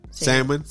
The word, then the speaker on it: Samhain: Because, and you said it because I don't want to butcher the name, but I think Samian. – Samhain. Yeah.